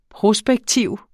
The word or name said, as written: prospektiv